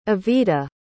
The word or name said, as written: evetta